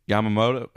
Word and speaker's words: Yamamoto: And his three free agent picks for the Cards is Nola, Monty, and Yamamoto.